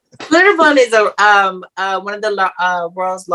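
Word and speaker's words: Schlitterbahn: But it's not spelt that way. – Oh. – It's spelled like it's German. – Yeah. – Oh, I know what you're talking about. Slitterbond.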